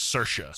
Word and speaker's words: Saoirse: It's S-A-O-I-R-S-E, Saoirse.